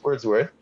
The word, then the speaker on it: Wordsworth: I believe I'm mispronouncing it. Correct me if I'm wrong. – You got it right. It's Wordsworth.